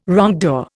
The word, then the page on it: wrongdoer: Indian